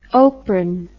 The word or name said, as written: Opren